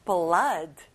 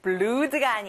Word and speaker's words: blood: P, blood. O, O가 두 개면 원래 U 소리 나는데 blood가 아니라 blood.